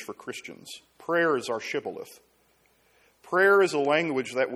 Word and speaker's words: Shibboleth: And so they say Shibboleth. Good Jewish word. Say Shibboleth. And the foreigners knew all the letters, but they couldn't pronounce it. And so they said Thibboleth.